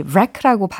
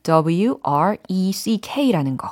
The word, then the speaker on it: wreck: 특히 이 wreck라고 발음을 하는 단어의 경우는 철자가 W R E C K라는 거. – Yes. 예, A가 빠진다는 거. – wreck.